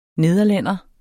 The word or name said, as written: nederlænder